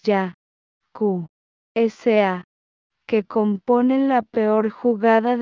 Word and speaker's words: Yakuza: Getty, la palabra Yakuza procede de los números 8, 9, 3. Pronunciados en japonés ya, ku, sa,